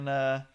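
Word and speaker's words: Matuszak: Matusik, yeah, using my skills of Polish language, Matusik, and it's like.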